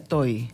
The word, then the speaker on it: Tohi: T-O-H-I. ¿Y? Toji. – Toji. – Se pronuncia Toji.